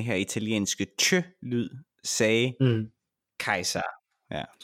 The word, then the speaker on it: Caesar: Jamen Romeriet øh, efter Augustus, som bliver enehersker, øh, kommer så, får så en række, lang række eneherskere, dem kalder vi kejser efter Julius Caesar, som faktisk udtales kejser på øh, latin.